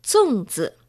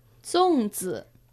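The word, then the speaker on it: Zongzi (粽子): Zongzi. Zongzi.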